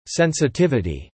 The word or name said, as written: sensitivity